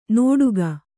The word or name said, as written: ನೋಡುಗ